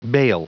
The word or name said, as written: bale